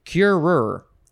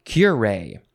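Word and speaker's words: curé: Next is cure or cure. You can emphasize either syllable. I think I will say cure. It's spelled like cure, but there's an accent on the E.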